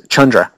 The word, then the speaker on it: Chandra: Chundra. I found out we've been pronouncing it wrong all along. – It's Chundra? – It's Chundra. – Chundra. Rhymes with Chundra.